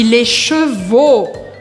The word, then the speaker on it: chevaux: E aqui mais uma dica de pronúncia, porque é diferente les cheveux, os cabelos, de les chevaux, os cavalos.